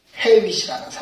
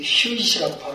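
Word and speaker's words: Hewitt: 찬송 428장 펴보시면 이 찬송시의 작, 작, 시자가 이 헤윗이라는 사람입니다. 이 휴잇이라고 바로 발음해야 되겠네요.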